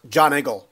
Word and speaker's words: Giant Eagle: I'm just picturing you strutting around Giant Eagle with that shopping cart right now. That's pretty good. I'm sorry. I guess in Pittsburgh it's pronounced John Eagle.